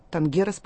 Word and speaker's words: тангерос: Танго, тангерос, по-моему, вы себя называете, да, Валентина? – Да. – Правильно да. – Ставлю ударение? – Да, все верно.